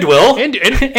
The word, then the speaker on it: andouille: So you get andouille, egg, and cheese on a French baguette. It was fantastic. – Thank you for pronouncing that correctly, too.